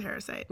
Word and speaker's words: Harrisite: Yes, he's a Harrisite. – Which rhymes with parasite.